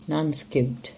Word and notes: Nancekuke: English

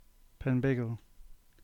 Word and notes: Penbeagle: English

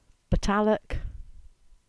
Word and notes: Botallack: English

